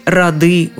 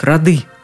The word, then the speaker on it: роды: Появляется в разных ситуациях с разными ударениями, в разных формах, запутывает, озадачивает – Смотрю недавно новости по телевизору, а ведущий говорит, в наступлении участвуют разные роды войск. Нет, роды войск, роды. Род, роду, рознь.